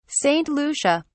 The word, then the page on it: Saint Lucia: British English